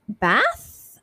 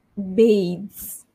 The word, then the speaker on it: bathes: So it's actually bathes. Okay, for this one, you have to have the diphthong, the two vowel sounds. So it's bathe, so it's not ba, it's bathes, bathes. So you land on a z sound, bathes.